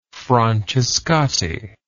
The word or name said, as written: francescatti